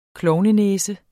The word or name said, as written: klovnenæse